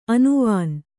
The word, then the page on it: ಅನುವಾನ್: anuvān